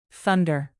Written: thunder

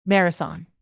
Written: marathon

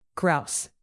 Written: grouse

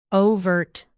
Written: overt

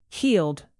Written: healed

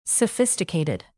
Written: sophisticated

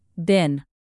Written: been

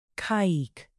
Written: caique

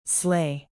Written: slay

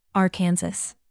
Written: arkansas

